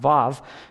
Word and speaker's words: Vav: Because Vav, So yours may say W-A-W, but it'd be pronounced Vav.